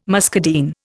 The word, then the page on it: muscadine: Indian